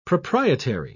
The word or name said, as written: proprietary